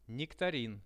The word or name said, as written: nektarin